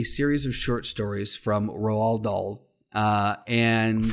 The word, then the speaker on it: Roald Dahl: R-O-A-L-D, Roald Dahl.